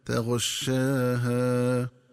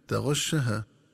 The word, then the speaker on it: تغشاها: ينبغي التنبه للنطق الصحيح للفظ تغشاها، فيقرأ بفتح الشين وألف بعدها هكذا تغشاها، ومن الخطأ قراءته بفتح الشين من دون ألف هكذا تغشها.